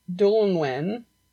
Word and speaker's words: Dwynwen: It's spelled D-W-Y-N-W-E-N. – Oh, interesting. – Yeah, so it's not pronounced at all how it's spelled.